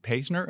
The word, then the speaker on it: Paisner: Rich Finneran from Bryan Cave, Layton & Paisner. Am I pronouncing the additional two names correctly? – Yeah, right. They're new names. Yeah, but yes, you got them right.